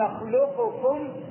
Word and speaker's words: نخلقكم: صفة الاستعلاء كيف ننطق؟ ألم نخلقكم. نخلقكم انتبه يعني لا نظهر القاف مخلقلة باعتبارها حرف خلقلة ونقول ألم نخلقكم. لا خطأ. لا طيب، وإنما ندغم القاف ونبقي صفة الاستعلاء. صفة الاستعلاء يعني ايه؟ يعني اللسان يرتفع الى الحنك الاعلى عند النطق بالقاف، فنقول ايه؟